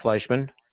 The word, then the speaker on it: Fleishman: Sure, it's Mark Fleischman. – Okay. Spell that first name, please. – M-A-R-C. Last name is F-L-E-I-S-H-M-A-N.